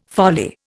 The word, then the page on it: folly: Indian